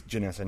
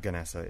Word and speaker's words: Ganessa: I think it's Ganessa, not Ganessa. It's spelled with a G.